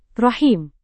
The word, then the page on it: rahim: Indonesian